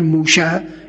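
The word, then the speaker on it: موشه (משה): حضرات یهود نوعاً شین را میدونید که شین تلفظ میکنن دیگه، موسا را میگن موشه، اسم میگذارن برای خودشون موشه، موشه دایان، موشه، بله از اینجور چیزها، بله موشه اسم میگذارن.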